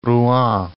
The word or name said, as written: Rouen